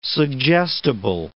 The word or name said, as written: suggestible